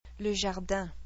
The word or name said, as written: Le jardin